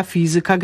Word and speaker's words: fizyka: Mówimy oczywiście matematyka, fizyka, gramatyka, czyli akcentujemy pewne wyrazy na trzeciej sylabie od końca, bo to są wyrazy pochodzenia łacińskiego.